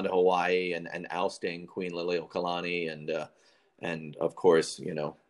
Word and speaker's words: Liliuokalani: By the way, Liliuokalani correctly.